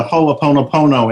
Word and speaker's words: Ho'oponopono: Oh, I love all these people who are so into Ho'oponopono, and you did say it correctly.